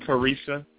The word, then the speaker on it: Charisa: Is it Charisa? – Yeah, you're actually saying it right.